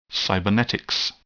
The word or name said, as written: cybernetics